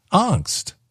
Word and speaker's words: angst: How do you say A-N-G-S-T? A-N-G-S-T. And I said, angst. And he said, it's not angst. It's angst. It's a hard A or angst.